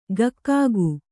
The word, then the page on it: ಗಕ್ಕಾಗು: gakkāgu